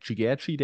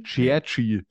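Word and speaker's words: Ciğerci: dass er anscheinend anders ausgesprochen wird. – Wie war das? Cherchi? – Cherchi. Okay.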